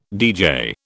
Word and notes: deejay: British English

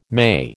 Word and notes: May: American English

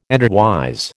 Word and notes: endwise: American English

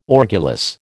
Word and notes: orgulous: American English

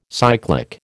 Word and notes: cyclic: American English

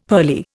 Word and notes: pearly: Indian

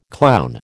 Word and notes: clown: American English

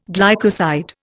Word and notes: glycoside: Indian

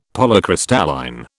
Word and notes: Polycrystalline: British English

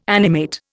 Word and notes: animate: Indian

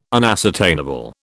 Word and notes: unascertainable: British English

